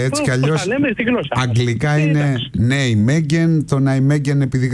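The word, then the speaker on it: Nijmegen: Η Ολλανδική όμως, λέει, προφορά είναι Νιουμενχάιν. Είναι κάπως βαθύ το χ. Δεν μπορώ να το πετύχω.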